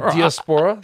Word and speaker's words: diaspora: Did you say diaspora? – Yeah, diaspora. So that's how you say it.